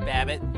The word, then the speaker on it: Babbitt: Yeah, Babbitt. Rhymes with rabbit.